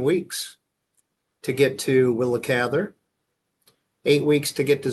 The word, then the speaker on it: Cather: Uh, in our last week's conversation about uh, Willa Ka- Cather, Cather, I'm never quite sure how to pronounce her last name. – Cather. Uh, Cather